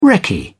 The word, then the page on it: recce: British English